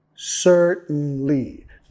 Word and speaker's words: certainly: Certainly. Note que estamos acentuando la primera sílaba.